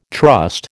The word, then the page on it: trust: American English